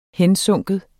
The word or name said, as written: hensynke